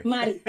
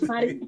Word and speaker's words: Mari: It's Mari, not Mary. – Mari. It is Mari.